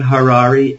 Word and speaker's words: Harary: After They Came is my book, and my name is Dan Harary, H-A-R-A-R-Y, rhymes with Ferrari.